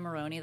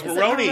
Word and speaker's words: Maroney: I always just am like, is it Marone? – No, it's Maroney. – Is it Maroney? – Maroney.